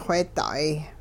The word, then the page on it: wheeda'ay: hupa